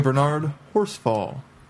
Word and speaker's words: Bernard Horsfall: Actor Bernard Horsfall.